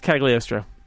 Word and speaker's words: Cagliostro: We're going to go with Cagliostro. Don't pronounce the G. Mm. – It's Cagliostro.